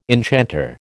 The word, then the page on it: enchanter: American English